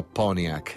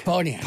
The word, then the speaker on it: Pontiac: In gergo, locale, in gergo locale si pronuncia Pontiac. Pontiac.